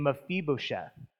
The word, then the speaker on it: Mephibosheth: This is a story, and this is how I believe you actually pronounce it. It's of a guy named Mephibosheth.